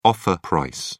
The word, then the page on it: offer price: British English